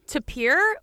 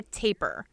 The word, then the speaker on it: tapir: So you can say it both ways, tapir or taper. And yes. – I've always said it tapir. They are both correct.